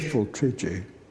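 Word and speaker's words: Pfifltriggi: It's pronounced Fiffle Trigi? – Fiffle Trigi. – Now I can die because I've always wondered.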